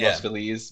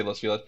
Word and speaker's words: Los Feliz: It's supposed to be Los Feliz, but it's, people say Los Feliz.